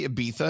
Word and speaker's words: Ibiza: well, they're hanging out in, in in Ibiza, and by the way, it's spelled Ibiza, but they say Ibiza, and I've never understood that, but I'm going to go with it.